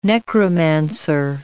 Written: necromancer

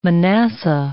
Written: Manasseh

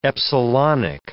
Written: epsilonic

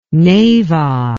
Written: Neiva

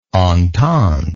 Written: ententist

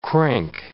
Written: crank

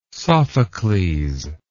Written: sophocles

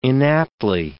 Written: inaptly

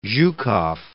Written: Zhukov

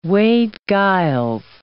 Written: Wade-Giles

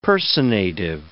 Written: personative

